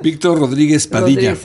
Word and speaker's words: Víctor Rodríguez Padilla: Víctor Rodríguez Padilla.